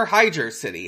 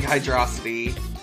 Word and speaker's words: Hydrocity: So they move on from, they move on to Hydrocity, or Hydra City, however you choose to pronounce it. – It's Hydrocity. – Yeah, I, in my heart, I think Hydrocity.